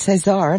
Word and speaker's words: Cesar: I just put it into a pronunciation guide, and it's Cesar, and I was correct, and you were wrong, and I'm so happy about that.